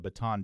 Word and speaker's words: Bataan: You know, we usually say Bataan, the Bataan Death March. That clearly is not correct. – No, this is confusing. They say it should be pronounced Bataan, like a Bataan.